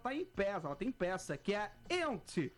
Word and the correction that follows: Yentl: Ent in Concert. – Yentl. – Eu vou fazer com pronúncia Yentl in Concert.